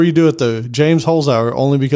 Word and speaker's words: Holzhauer: Holzhauer? Holzhauer? – Yeah, Holzhauer. That's pretty good.